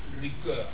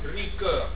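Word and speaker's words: Ricœur: Acostúmbrense a decirle Ricoeur, por lo menos, porque va a ser más difícil decir Ricoeur, es muy complicado. Hay que mover la boca así, entonces, más vale que lo pronuncie antes de pronunciar el francés.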